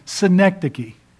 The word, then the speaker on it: synecdoche: There is a rhetorical device in the New Testament called synecdoche. That rhymes with schenectady, New York, as in New York. Now look at your neighbor and say, synecdoche.